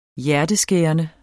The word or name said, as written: hjerteskærende